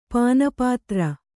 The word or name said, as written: ಪಾನಪಾತ್ರೆ